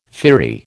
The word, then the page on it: firry: American English